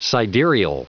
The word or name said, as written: sidereal